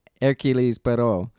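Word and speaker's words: Hercule: The first Hercules, but it's not pronounced Hercules. It's Hercules Perot.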